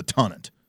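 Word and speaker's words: Tonnant: The British usually go with the long first syllable, so I would say it's the Tonant.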